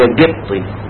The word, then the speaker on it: قبطي: فالنطق الصعيدي قبطي, هم في ناس يقول لك نحن الأقباط, أقباط كمان خلوها زي كلمة قال وقال, فبيقول لك الأقباط. طبعا ما كل دي خطأ نتيجة انما الكلمة أصلية قبطي, هم يفتكروا بالناس ان ده نطق صعيدي, وبعدين يحب هو لما ينطقها بالطريقة البحيري يقول قبطي, يقول لك انا قبطي قبطي واقباط, هي مش اقباط خالص, فهي قبطي نطق صعيدي اصح يعني.